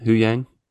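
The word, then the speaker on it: Huyang: So, meanwhile, while she's you know training with Ahsoka and Hu Yang, is that how you say it? Hu Yang. – Yes, Hu Yang. Like Wu Tang? No, not the same.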